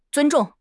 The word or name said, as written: zun1 zhong4